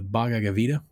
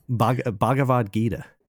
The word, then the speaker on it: Bhagavad Gita: The Bhagavad Gita. – Bhagavad Gita.